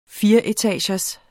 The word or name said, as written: fireetagers